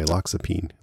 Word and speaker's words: Loxapine: But anyway, she's taking this medication called Loxapine, which is most likely a reference to, well, it's pronounced the same way, Loxapine.